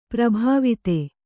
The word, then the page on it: ಪ್ರಭಾವಿತೆ: kannada; prabhāvite